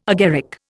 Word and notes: agaric: Indian

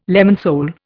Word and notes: lemon sole: Indian

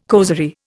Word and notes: causerie: Indian

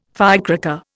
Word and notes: firecracker: Indian

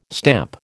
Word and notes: stamp: American English

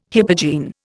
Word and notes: hypogene: Indian

